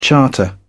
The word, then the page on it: charter: British English